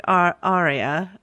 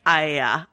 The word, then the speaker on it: Arya: The Arya one is hard because sometimes in the show they pronounce it sort of like Arya, like a regular English accent person would. And then sometimes it's whatever Michelle Fairley's saying, which is like Aya.